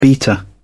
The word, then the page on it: beta: British English